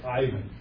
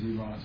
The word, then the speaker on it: Ivan: You and I would read it and say Ivan. But the Russian pronunciation is Ivan.